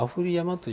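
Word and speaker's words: 雨降山: この他にもね、あと全部でどれぐらい 5 つぐらいあるんですかね、他では雨降り山って言ってるんですけど、ここはなぜかアフリ山という、まあガイド試験で読み方はまあ出ることはないんですけれども、一応念のためにここは一応アフリ山と。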